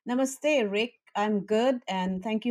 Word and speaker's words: Namaste: Yes, and thank you for correcting my pronunciation. Namaste. I got to get that right.